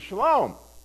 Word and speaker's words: shalom: In Hebrew, the word peace, you've probably heard it, is shalom, right? You hear that that's a shin, that's a S sound, and then you hear aleh, which that's a lamed, and then you hear that mm sound, and that's a mem, okay?